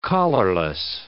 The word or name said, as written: collarless